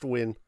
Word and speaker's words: Nguyen: It's usually pronounced Nguyen. – N-G-U-Y-E-N.